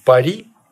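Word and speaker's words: Paris: Тебя не смущает, что замечательный город под названием Пари? – Очень смущает. – В русском языке стал Париж.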